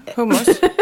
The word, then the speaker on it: hummus: Ja, hummus. – H U M M U S. H U M M U S. Det uttalas hummus, inte hummus. Visste du det? – Jag hör ingen skillnad.